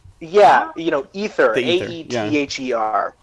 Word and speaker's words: Aether: Ether. Okay. – Yeah, Ether.